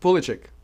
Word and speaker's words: Pulisic: Christian Pulisic? – Pulisic, yeah. – Is he good? – Very good. – You pronounce that correctly. – Pulisic.